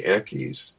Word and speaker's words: Equis: But this one snake was called the Equis, sort of like, I don't know if you drink beer or not, but like the Dos Equis beer from Mexico. It's spelled E-Q-U-I-S.